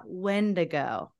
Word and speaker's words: Wendigo: So So his favorite is the Wendigo.